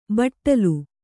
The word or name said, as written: ಬಾಟಲಿ